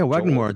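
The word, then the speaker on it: Wagner Moura: Her, I guess, journalist friend, played by, I don't know how to say his name, Wagner Mora? – Yeah, Wagner Mora.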